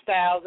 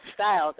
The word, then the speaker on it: Styles: My name is Chris Styles. It's spelled. Everybody think it's a drink. It's not the drink. Oh, I think about changing my name. I swear it is. Okay, it's C-R-Y-S, apostrophe, space, Styles, S-T-Y-L-E-S.